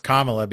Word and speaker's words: Kamala: I know it's pronounced Kamala, but you could have Kamala Palooza too.